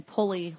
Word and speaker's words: Pulley: It's R-E-B-E-K-A-H. – Yeah. – And then Pulley, like the simple machine, P-U-L-L-E-Y.